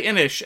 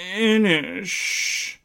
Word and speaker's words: Innis: But anyway, it's spelt Innis. People say Innish, and if you say Innis, they get very, very snooty about it. Oh, don't you know? It's pronounced Innish.